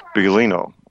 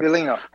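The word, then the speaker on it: Biglino: Mauro, they don't pronounce the second syllable. Bilino is how they say it. – Bilino, okay, right.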